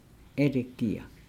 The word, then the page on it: elegia: Nukuoro